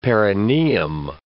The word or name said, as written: perineum